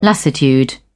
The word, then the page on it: lassitude: British English